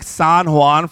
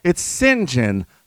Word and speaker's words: St. John: Is that how you pronounce it? Dude, I've been calling you like San Juan for the last 30 pages. No, dear girl, it's Sin Jin, like the sins I don't commit, you know, and the gin I don't drink.